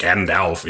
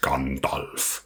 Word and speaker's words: Gandalf: And it's not Gandalf, it's Gandalf. Fantastisch.